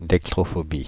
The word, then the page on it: dextrophobie: /dɛks.tʁɔ.fɔ.bi/; France (Île-de-France)